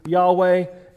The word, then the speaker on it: Yahweh: I believe it's more closely pronounced to Yahweh, and many Hebrew scholars and rabbis confirm, although they do not use that name.